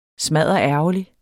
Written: smadderærgerlig

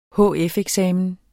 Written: hf-eksamen

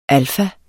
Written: alfa

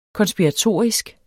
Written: konspiratorisk

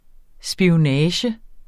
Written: SPIONAGEN

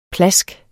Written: PLASK

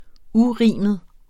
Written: urimet

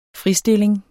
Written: fritstilling

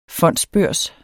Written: fonds

